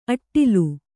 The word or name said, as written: ಅಟ್ಟಲು